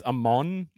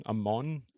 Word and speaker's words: Amon: My recommendation is for anyone who's never heard, and it's Amon, like Egyptian, Amon, A-M-O-N.